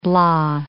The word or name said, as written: blah